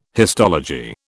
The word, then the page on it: histology: British English